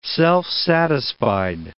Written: self-satisfied

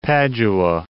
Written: Padua

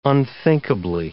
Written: unthinkably

